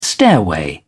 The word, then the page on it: stairway: British English